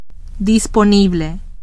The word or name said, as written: disponible